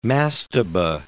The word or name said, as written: mastaba